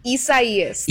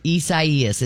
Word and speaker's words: Isaias: So, how do you pronounce that? She's saying... – Isaias. – Isaias? – Isaias. It's Spanish for Isaiah.